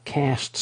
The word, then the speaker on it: caste: And I learned how to pronounce this word, C-A-S-T-E. It's pronounced cast.